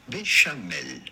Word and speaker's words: béchamel: How do you go about pronouncing it in French? Because this is certainly a French word. This would be pronounced béchamel.